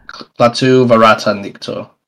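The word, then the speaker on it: Klaatu barada nikto: Ash is supposed to speak to a mysterious phrase when he takes the Necronomicon, Calatu Verta Nicto. I probably butchered that. – No, no. Calatu Verta Nicto. – Right, there you go.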